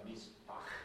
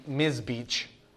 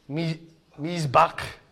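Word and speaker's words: Mizbach (מזבח): It's Mizbach. – Yeah, it's not Mizbeach. They don't have a CH sound like that. Mizbach.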